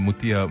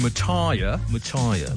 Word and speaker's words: Muthiah: And the Australians used to call him Muthiah, like he was a tyre, yeah.